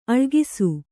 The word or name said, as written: ಅಳಕಿಸು